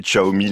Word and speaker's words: Xiaomi: Et oui Pierre, et comme vous l'avez bien prononcé, Xiaomi, les Français découvrent actuellement cette marque et ses smartphones au rapport qualité-prix imbattable.